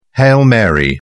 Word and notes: hail mary: British English

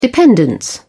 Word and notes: dependence: British English

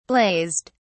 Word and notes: blazed: British English

